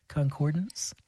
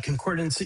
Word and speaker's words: Concordance: Concordance? – No, Concordance, you know, there's a little bit of a pun on the title of the piece, the Concord Sonata, with dance in there.